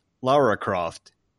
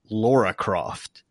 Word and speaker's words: Lara Croft: Speaking of, for example, if you did not know that it's pronounced Lara Croft instead of Laura Croft, then you may want to skip ahead and come back to us later.